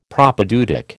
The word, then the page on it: Propaedeutic: American English